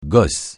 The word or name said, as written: gosse